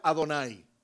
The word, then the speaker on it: Adonai: Porque los judíos ponían, se escribe, y ponían el tetragramatón, y al lado ponían, se pronuncia Adonai. Por eso los judíos dicen Adonai, Adonai.